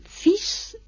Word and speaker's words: fils: Fis es hijo, fis, escrito F-I-L-S, es fis, es como si no llevara la L prácticamente, es el masculino,